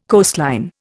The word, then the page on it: coastline: Indian